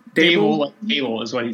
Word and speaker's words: Dabole: Dable is what he told us. – Dable? – Dable like table. – Doesn't look like that. It's like D-A-B-O-L-E, but okay.